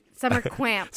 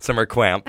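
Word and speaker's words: Summer Qamp: And again, it's Summer Camp with a Q. – Summer Camp with a Q. Yeah, yes. I said it's hard to pronounce. – It's Summer Quamp. – Summer Quamp.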